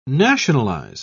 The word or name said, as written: nationalize